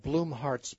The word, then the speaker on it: Blumhardt: It's called Bloomheart's Battle. Bloomheart is spelled B-L-U-M-H-A-R-D-T, Bloomheart.